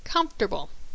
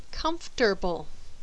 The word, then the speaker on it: comfortable: In this word, the second vowel is skipped, and the A is also skipped, plus the RT is reversed and pronounced as tur. So this ends up sounding like comfortable. There are some native speakers who are careful with this word and articulate the written order of the letters, comfortable.